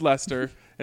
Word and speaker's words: Leicester: That would be L-E-S-T-E-R if we're going Leicester. – Well, it's pronounced Leicester, and Leicester City won the Premiership.